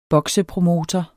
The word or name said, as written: boksepromotor